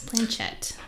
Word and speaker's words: planchette: And on March 27th, in 1938, he conducted a planchette seance. Planchette, right? That's how you say it. – Yeah, planchette.